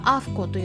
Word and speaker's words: AAFCO: それでですねペットフードの場合ワンちゃん用でも猫ちゃん用でもどちらでもですねアメリカの場合はアフコといって AAFCO と書いて